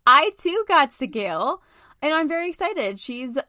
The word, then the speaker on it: Seagal: I took this test and I allegedly got, how do you say it, Seagal? – Seagal.